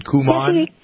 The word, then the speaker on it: Kumon: Am I pronouncing that right? Dominique, is it Kumon? – Yes, it is.